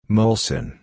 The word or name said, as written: Molson